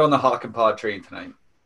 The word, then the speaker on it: Hakanpää: Yeah, Hakanpää.